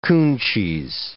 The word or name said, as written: coon cheese